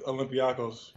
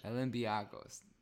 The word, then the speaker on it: Olympiakos: Yeah, I'm with Olympiakos. – Olympiakos.